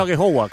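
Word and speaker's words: Aghehowa: he dicho Agejogwa, ¿cómo se pronuncia? – Sí, bien, bien, la verdad que...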